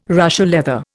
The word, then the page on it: Russia leather: Indian